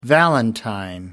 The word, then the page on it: valentine: American English